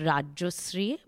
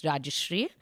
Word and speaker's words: Rajyasree: So my name is pronounced Rajasri, but because I am sadly in Delhi, people all say Rajeshwari and whatnot. So just to make life simpler, I also say that my name's Rajasri.